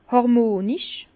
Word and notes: hàrmonisch: Bas-Rhin; Herrlisheim

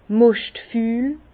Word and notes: moschtfül: Bas-Rhin; Herrlisheim

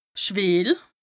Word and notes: schwìel: Bas-Rhin; Strasbourg

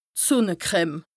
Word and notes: d' Sùnne-Creme: Bas-Rhin; Strasbourg

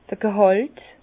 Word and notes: d'r G'hàlt: Bas-Rhin; Herrlisheim

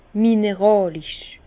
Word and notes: mineràlisch: Bas-Rhin; Herrlisheim

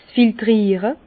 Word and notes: 's Filtriere: Bas-Rhin; Herrlisheim

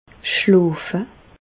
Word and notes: schloofe: Bas-Rhin; Reichshoffen

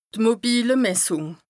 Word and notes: d' mobile Messùng: Bas Rhin

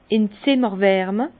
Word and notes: in d' Zimmerwärme: Alsatian; Bas-Rhin; Herrlisheim

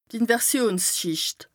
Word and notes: Inversionsschìcht: Bas Rhin

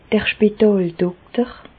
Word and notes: d'r Spitàldokter: Bas Rhin; Reichshoffen

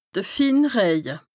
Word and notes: de fin Rëje: Bas-Rhin; Strasbourg

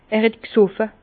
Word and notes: er het g'soffe: Bas-Rhin; Herrlisheim

